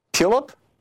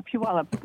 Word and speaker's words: Puyallup: Puyallup. You're on the air. – Oh, hi. Hi. Yeah, Puyallup.